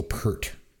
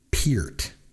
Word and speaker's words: Peart: The drummer from Rush was not Neil Peart. It's pronounced Neil Peart. Like ear, with a P at the beginning and a T at the end.